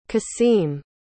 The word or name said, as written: kaseem